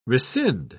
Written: rescind

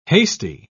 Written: hasty

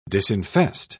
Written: disinfest

